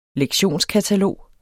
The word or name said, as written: lektionskatalog